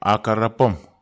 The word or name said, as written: akhar apam